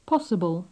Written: possible

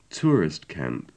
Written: tourist camp

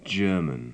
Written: german

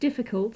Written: difficult

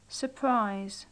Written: surprise

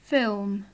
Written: film

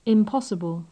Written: impossible

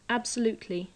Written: absolutely

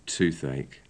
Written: toothache

